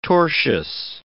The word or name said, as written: tortious